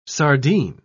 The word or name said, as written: sardine